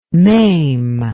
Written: mame

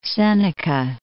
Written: Seneca Lake

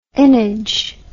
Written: ennage

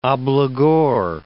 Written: obligor

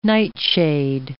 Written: nightshade

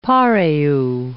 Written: pareo

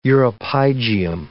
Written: uropygium

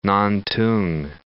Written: Nantung

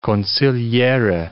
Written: consigliere

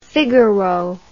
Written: figaro

